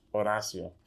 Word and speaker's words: Horacio: So what happened was, my name is Horacio. That's how you say it in Spanish. Well, that's how you should say it all the time.